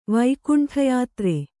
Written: ವೈಕುಂಠಯಾತ್ರೆ

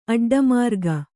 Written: ಅಡ್ಡಮಾರ್ಗ

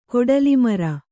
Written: ಕೊಡಲಿಮರ

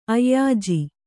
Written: ಅಯ್ಯಾಜಿ

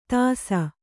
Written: ದೆಸೆ

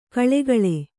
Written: ಕೋಳ್ಗೊಳ್

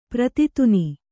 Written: ಪ್ರತಿತುನಿ

